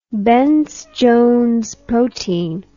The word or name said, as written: bence jones protein